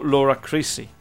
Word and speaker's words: Laura Crisci: Ah, bene, allora potete cercare su YouTube il video di questa canzone Winter Song di Laura Crisci, un nome che gli americani pronunciano Laura Crisci, right? – Laura Crisci.